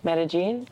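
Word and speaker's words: Medellín: Pronouncing Medellin. Medellin.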